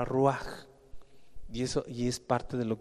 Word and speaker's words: ruaj: La palabra espíritu, hermano, viene del hebreo ruaj. Ruaj, R-U-A-J con acento en la U, ruaj.